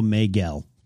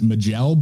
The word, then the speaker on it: Maegelle: I go Maegel. – That's the same way I'm going, Maegel. So I take that from Maegor. So yeah. – Maegel was born. – M-A-E-G-E-L-L-E. Thanks, George. – Yeah. I'm almost tempted to pronounce it Magel, but I got to stop myself from doing that.